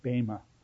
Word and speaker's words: Bema: Now, the other use of the term Bema, please, it's not Bema, it's Bema. B long E M A. But it's an Eta in Greek. A is your long E. A as in they.